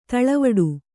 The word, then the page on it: ದಳವಾಡ: daḷavāḍa